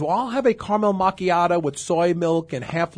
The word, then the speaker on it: macchiato: Well, you mentioned the caramel macchiato, but the white chocolate mocha is really worse than the caramel macchiato.